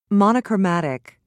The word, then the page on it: monochromatic: British English